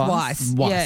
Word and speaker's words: Weisz: man, which is a woman, Rachel Weisz. Have I said that right? – Well, I think it's Weisz.